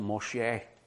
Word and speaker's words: Moshe: So, Moses, the name Moses, actually it's pronounced Moshe, but we'll stick with Moses.